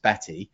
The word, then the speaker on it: Batte: Well, I used to introduce her actually as Catherine Bat from the Daily Mail for about two years until she finally told me, despite semi regular contact between the two of us, that her name's actually pronounced Batty. Um, so it's Kathryn Batte from the Daily Mail, formerly just the Daily Mail's women's football correspondent, but now also BBC TV superstar.